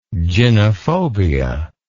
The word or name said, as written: gynephobia